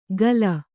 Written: ಗಲ

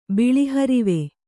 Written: ಬಿಳಿಹರಿವೆ

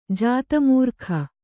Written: ಜಾತಮೂರ್ಖ

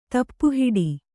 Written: ತಪ್ಪುಹಿಡಿ

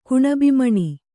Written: ಕುಣಬಿಮಣಿ

